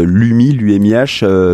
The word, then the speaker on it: UMIH: On peut dire les deux, j'avais entendu UMI, c'est pour ça que j'ai commencé comme ça. – Ça s'écrit UMIH.